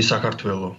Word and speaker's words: Sakartvelo: We say Sakartvelo.